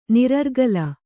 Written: ನಿರರ್ಗಲ

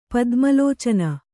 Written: ಪದ್ಮಲೋಚನ

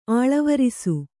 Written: ಅಲವರಿಸು

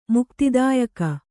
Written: ಮುಕ್ತಿದಾಯಕಿ

